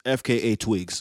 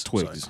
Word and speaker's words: FKA twigs: FKA Wigs? – Hold on here. I do not want to mispronounce. FKA Twigs. – Twigs.